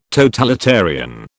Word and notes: totalitarian: British English

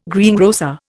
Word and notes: greengrocer: Indian

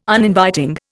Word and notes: Uninviting: Indian

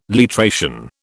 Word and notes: literation: British English

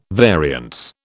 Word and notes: variance: British English

